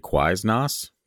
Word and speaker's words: Quiznos: I love when people say Quiznos instead of Quiznos. It's the best. Anyway. – What if we said it wrong and they're laughing at us for it? It's actually Quiznos, but yeah.